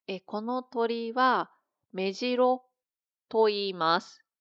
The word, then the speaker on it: 目白: 目白、この言葉もあります。目白、アクセント 1 です。